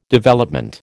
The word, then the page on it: Development: American English